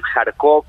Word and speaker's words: Kharkov: Primero, definitivamente Rusia está haciendo avances, y estos avances están en la zona noreste, está amenazando la zona de Kharkiv, Kharkov, como decías tú, uh-huh. de distinta forma se puede pronunciar.